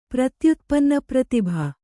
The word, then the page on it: ಪ್ರತ್ಯುತ್ಪನ್ನಪ್ರತಿಭ: pratyutpanna pratibha